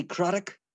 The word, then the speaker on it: Craddock: Worn by legends and Premier League winners Dennis Irwin and Paul Ince, as well as some retro men, Kenny Miller, Stefan Everson, Jody Craddock, rhymes with Haddock,